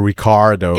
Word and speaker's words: Ricciardo: Aber er ist aus Australien. und, und, sie sagen Ricardo.